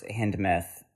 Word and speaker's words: Hindemith: Originally, the score's Hindemith. And am I saying that right? – Yeah.